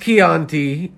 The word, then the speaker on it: Chianti: It's pronounced Chianti, and you didn't even say the question.